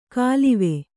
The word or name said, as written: ಕಾಲಿವೆ